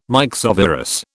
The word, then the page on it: myxovirus: British English